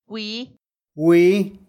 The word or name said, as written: gwii